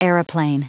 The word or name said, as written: aeroplane